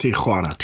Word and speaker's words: Tijuana: And it's pronounced It's pronounced Tijuana. – Tijuana.